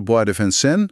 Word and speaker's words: Bois de Vincennes: Precies, dat komt bijna helemaal door de Bois de Vincennes.